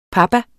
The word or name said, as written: papa